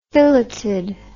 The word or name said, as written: filleted